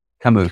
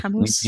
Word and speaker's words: Camus: Camus. Camus.